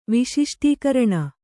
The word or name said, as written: ವಿಶಿಷ್ಟೀಕರಣ